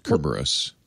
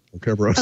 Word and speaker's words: Kerberos: Is that how you say that? Cerberus. – Kerberos. – Kerberos. – Oh, Kerberos. I think I said this wrong the last time.